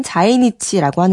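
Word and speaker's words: 자이니치라고 (在日): 이렇게 일본의 식민지를 계기로 일본에 살게 된 조선인과 후손이 우리가 흔히 말하는 제일교포, 일본에선 제1의 일본 발음인 자이니치라고 하는데요.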